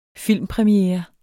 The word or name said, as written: filmpremiere